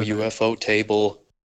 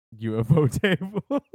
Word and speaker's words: ufotable: Studio UFO table. – UFO table. – I've heard it pronounced that way, and I never know if it's actually how it's supposed to be said. – It's ufotable.